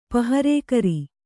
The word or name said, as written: ಪಹರೆಗಾರ